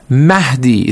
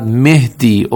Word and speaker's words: Mahdi: For example, it is Mahdi. It is not Mehdi or Mehdi or something like that.